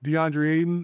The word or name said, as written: Deandre Ayton